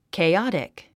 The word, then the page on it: chaotic: keiɑ’tik; ケェオティク